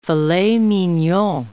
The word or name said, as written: filet mignon